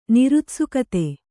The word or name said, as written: ನಿರುತ್ಸುಕತೆ